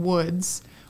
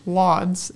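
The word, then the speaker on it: Łódź: Scandici took down Woods, which we learned that that is the pronunciation for what we were calling Lods. It's Woods. – The LW switch. – The L with the cross is a W.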